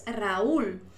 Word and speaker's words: Raúl: porque el nombre no es Raúl, es Raúl. Para que esta U, que es una vocal débil, suene más fuerte que la A, hace falta poner la tilde. Y así tenemos dos sílabas, ¿verdad?